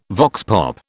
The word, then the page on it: vox pop: British English